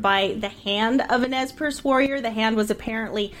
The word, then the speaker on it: Nez Perce: John Griswold's wife was a member of the Nez Perce tribe.